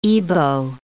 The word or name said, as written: Ibo